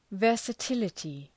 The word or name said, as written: versatility